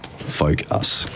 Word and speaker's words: Folkus: Like, sounds like focus, but it's spelled folk, F-O-L-K-U-S, Focus.